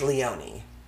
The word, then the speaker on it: Leone: The name is spelled L-E-O-N-E. – Mm. – Mm. Yeah, it's difficult. I went to school with a guy whose last name was L-E-O-N-E, and it was pronounced Leonie.